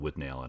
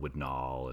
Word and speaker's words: Withnail: There's always different pronunciations here, but I've always called it Withnail and Eye. I know some people call it Withnall and yeah, Withnall, yeah.